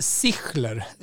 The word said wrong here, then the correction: Zischler: Sich Zischler, svårt att uttala. Z och SCH. E Zischler.